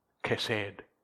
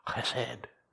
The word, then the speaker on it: chesed: It's my favourite Hebrew word, chesed. You have to say it right down the back of your throat. Chesed.